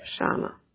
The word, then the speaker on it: Shana: And my name is spelled S like Sam, H like Harry, E like Apple, N like Nancy, N like Nancy, A like Apple. – Dana. Okay. – Yep, Shana.